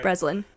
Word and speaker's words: Breslin: And then number 5, you have Abigail Breslin. Is that her last name? Did I say that right? – Breslin.